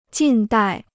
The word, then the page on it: 近代: jìn dài